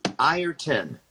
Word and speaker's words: Ireton: Ireton. See, it's spelled I-R-E-T-O-N, so it's backwards. – Backwards? – Well, I'm saying it sounds like there's an I-E-R, I-R-T-O-N, not, it looks like I-R-E-T-O-N.